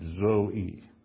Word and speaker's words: zoe: It's the word Zoe. That's the transliteration of the Greek word Z-O-E, long E, Zoe.